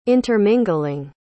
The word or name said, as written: intermingling